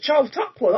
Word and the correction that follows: Chautauqua: that's how you say it, isn't it? – Michael, can we have a correct pronunciation, please? – Chautauqua. That was great.